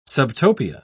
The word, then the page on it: subtopia: British English